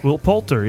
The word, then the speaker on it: Poulter: Let's celebrate our next birthday boy, Mr. Will Poulter. Is that how you say it? Poulter? – Will Poulter, yeah.